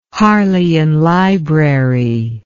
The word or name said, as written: Harleian Library